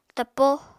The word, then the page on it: tapo: Apatani